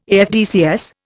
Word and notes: AFDCS: Indian

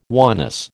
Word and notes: oneness: American English

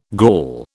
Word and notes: Gaul: British English

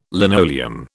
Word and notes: linoleum: British English

